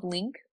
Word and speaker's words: LYNQ: The next one I have is called Link, and it's spelled really cute. It's LYNQ.